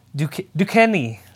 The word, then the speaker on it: Duquesne: Duquesne. I think that's how you say it. – Oh, okay. Good job.